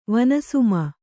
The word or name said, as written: ವನಸುಮ